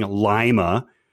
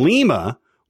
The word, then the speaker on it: Lima: However, he pronounces it the wrong way, saying Lima, as in the Ohioan city, rather than Lima, which is the correct pronunciation. So L-I-M-A, I should say.